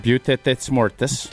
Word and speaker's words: Butte des Mortes: Port des Morts, which I'm not pronouncing it right. – Am I right? – It's like Butamore, right? Yep. Butamore. – Butte des Mortes.